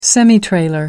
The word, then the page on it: semitrailer: American English